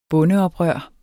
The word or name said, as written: bondeoprør